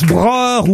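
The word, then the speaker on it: Broch: Alors, je sais pas si on prononce broche ou broche. – Broche. – Parce que moi, parfois, ça dépend s'il y a un umlaut sur le O. – Oui. – Il n'y a pas de umlaut sous le O.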